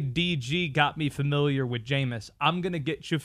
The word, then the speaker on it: Jameis: J-A-M-E-I-S, Jameis Winston. His name rhymes with famous. It's famous Jameis Winston.